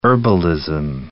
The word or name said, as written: herbalism